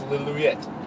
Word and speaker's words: Lillooet: It's Lillooet. – Lillooet. – Lillooet. – That's the, of course, pronunciation by our local resident.